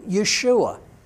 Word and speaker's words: Yeshua: Now, last week we talked about the mighty miracles that God was doing through Yeshua. That's how we say His name, Be'evrit, or in Hebrew, Jesus.